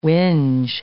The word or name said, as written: whinge